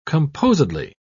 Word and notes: composedly: British English

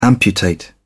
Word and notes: amputate: British English

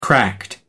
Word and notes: cracked: American English

